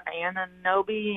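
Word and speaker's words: Anunoby: I've seen them connected with a Toronto player, Ananobi.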